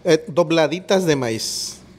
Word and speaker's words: dobladitas: But the dobladitas, am I saying that right? Yes? – Dobladitas de maíz.